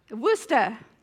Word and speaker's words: Worcester: Her name was Frances Habergal, and she was born in, well, I would say Worcestershire, like the sauce, but it might be Worcestershire. No? Worcester. That's not spelled like the Worcester in Massachusetts.